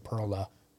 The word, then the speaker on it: Perla: No, so, like, they'll, like, Pearl Street, they say Perla, right? That's how you say it in Spanish.